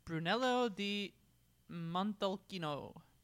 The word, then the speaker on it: Brunello di Montalcino: Well, the next one on the list, uh, sorry, first of all, on pronunciations, uh, Brunello di Montalcino. Yep. Brunello is the name of the local. – Nailed it.